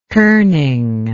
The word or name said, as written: kerning